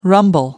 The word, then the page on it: rumble: American English